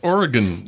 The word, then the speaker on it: Oregon: And, you know, at first I was saying Oregon. And I'm like, what are you doing? It's like, it's Oregon. It's like, what? – Oregon. – Oh, Oregon. It's really only two syllables, not three.